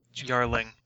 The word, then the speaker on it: Jarling: Would it be pronounced Jarling or Jarling? – Jarling. That's how Scandinavian languages do it.